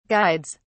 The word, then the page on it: guides: British English